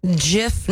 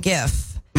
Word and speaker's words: GIF: It's pronounced GIF, not GIF. – No. – Just like the peanut butter. – No. – The Oxford English Dictionary accepts both pronunciations. Will Height told the New York Times they are wrong. It is a soft G pronounced GIF. – But then he writes it with a J-I-F.